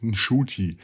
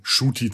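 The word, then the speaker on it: Ncuti: es ist Nshuti. Das N spricht man kaum aus und da Shuti Gatwa das bis zu seinem 26. selber falsch gemacht hat, ist es in Ordnung, Shuti zu sagen.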